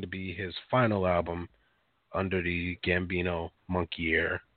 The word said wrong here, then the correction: moniker: Um Whoa, what was that? – Monkey Air. that, moniker? If I believe. – Is that how you say it, moniker? Mm-hmm. You sure? – I am an English major, but yes, it, it, so many great messages to kind of go um, along in this video.